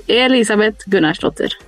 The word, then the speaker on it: Elisabet Gunnarsdottir: Elisabet Gunnarsdottir.